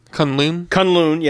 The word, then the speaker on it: Kunlun: Like, because he has, because he's kind of, it's kind of the Batman story in the sense that he goes away to I'm going to say it wrong. I think it's Kunlun. – Yes, I was about to say that. He goes away to Kunlun and then he comes back.